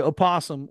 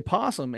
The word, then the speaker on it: opossum: So you can say opossum or you say possum and it's still spelled the same way.